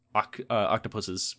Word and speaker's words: octopuses: The Soul of an Octopus is written by caretakers who have worked with octopus, you know, various octopuses, which is the correct way to say it, unfortunately.